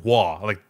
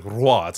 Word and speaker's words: Roy: Like, when I was growing up, I would say Patrick Roy, like W-A-H. But it's not okay. Roy, right? It's like Roy. It's a rolling R, right? – Okay.